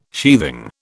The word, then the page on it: sheathing: British English